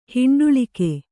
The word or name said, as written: ಹಿಂಡುಳಿಕೆ